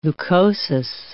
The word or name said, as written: leukosis